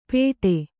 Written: ಪೇಠೆ

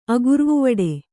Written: ಅಗುರ್ವುವಡೆ

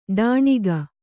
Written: ಡೊಣ್ಣೆಗ

